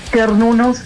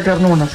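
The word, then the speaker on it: Cernunnos: En realidad la banda se pronuncia Kernunos con K.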